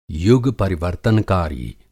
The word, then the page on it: युग परिवर्तनकारी: Hindi